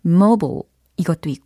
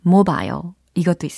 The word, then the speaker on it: mobile: 일단 철자는 M-O-B-I-L-E 이렇게 생겼죠. 그래서 발음에 두 가지 버전이 있어요. mobile 이것도 있고요. 그다음 mobile 이것도 있습니다.